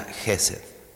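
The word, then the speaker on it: Hesed: El primer término es Gesed, así como suena Gesed. Se escribe con H en español, pero se pronuncia Gesed, que indica la parte bondadosa, fiel, amorosa, que puede resumirse en la expresión es un amor fiel.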